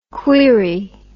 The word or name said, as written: queerie